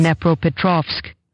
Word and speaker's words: Dnepropetrovsk: And it's Dnepropetrovsk.